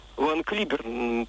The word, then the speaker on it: Ван Клиберн: в нашей стране ее всегда Ван Клиберн произносили, тем более, что это не английская, а по происхождению голландская фамилия. Да и мы же не говорим Шекспая или доктор Уотсон или Ингланд, мы говорим Шекспир, доктор Ватсон, Англия,